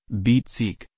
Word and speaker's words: Bezirk: Since the 2001 administrative reform, it has been made up of 12 boroughs or districts, German, Bezirk, pronounced Bezirk, each with its own local government, though all boroughs are subject to Berlin's city and state government.